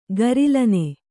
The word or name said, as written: ಗರಿಲನೆ